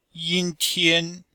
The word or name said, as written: yin1tian1